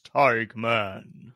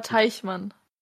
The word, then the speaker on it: Teichmann: Ja, ja, er heißt aber Teichmann. – Ja, aber die Engländer können oder die Amerikaner können kein CH aussprechen, so wie wir. Deswegen die machen aus machen die K.